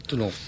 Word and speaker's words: Toulon: La no se pronuncia. Toulon.